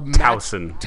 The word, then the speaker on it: Towson: I don't know how it's properly pronounced, but that terrible school that they beat by 50 points. I think the big question for this, and it's hard to talk about the Towson. Google says Towson.